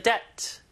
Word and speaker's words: debt: D 발음은 거의 무음이에요. Debt가 아니라 그냥 debt. B 없는 것처럼 발음하세요.